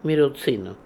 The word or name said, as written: Mirozinoo